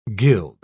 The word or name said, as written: gild